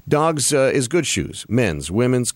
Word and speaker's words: Dawgs: But you're going to have to wait for it for just a minute because I want to tell you first about Dogs. Dogs is spelled D-A-W-G-S, and they sell shoes.